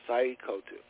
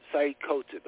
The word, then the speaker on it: Sayyid Qutb: Bro, you just mentioned Sayyid Qutb. He's pronounced his name Sayyid Qutb. That's how you pronounce it, but he spelled it S-A-Y-Y-I-D. And then his last name is Q-U-T-B.